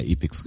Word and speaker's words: Epyx: Epics war Summer Games, Ich würde sagen Epics, also wie können wir es denn in Englisch aussprechen? Epics, also E P Y X.